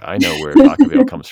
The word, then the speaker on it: Vacaville: There's a park in like Vacaville. Is that the way to pronounce it? – Yeah. I mean, I made it.